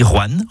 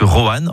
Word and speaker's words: Rouen: Vous avez remarqué comment je dis Rouen en une syllabe plutôt que Rouen en deux syllabes? C'est comme ça qu'on y dit dans la région.